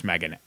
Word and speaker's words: M3GAN: It's M3GAN, and I still call 7 Sesevenin because if you're going to spell your movie S-E-7-E-N, I'm going to pronounce it Sesevenin because I'm an asshole. But yes, it's just called Megan, but the E's a 3. So if you're Googling it, you should spell it Mithrigan, but it's pronounced Megan.